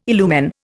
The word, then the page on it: illumine: Indian